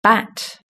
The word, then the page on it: bat: British English